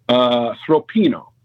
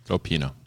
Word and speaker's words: Sgroppino: Sropino. – Okay, that sounded like you said throat and pee and no. – Sropino. – No? – So it's spelled S-G-R O-P-P-I-N-O.